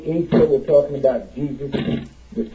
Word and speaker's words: Isa: We Muslims call him Isa because that is the pronunciation before you Latinize it.